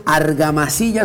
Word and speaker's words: Argamasilla: Y señores, señores, como estamos viendo aquí en la toma, tenemos a nada más y nada menos que Alejandro Argamasilla. Estamos pronunciando bien el apellido, está compadre.